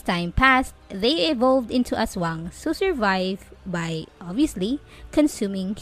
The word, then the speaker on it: Asuang: I completely forgot that we have a god named Aswang in Bicol, and it is spelled as A-S-U-A-N-G, Aswang.